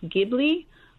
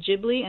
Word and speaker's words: Ghibli: It's actually, the word in Italian is pronounced Ghibli, but it said that he mispronounced it Ghibli, and so then it just stuck.